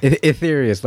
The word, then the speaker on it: Aetherius: Aetherius, like ether, basically, that's where that name comes from, ether.